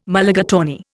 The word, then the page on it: Mulligatawny: Indian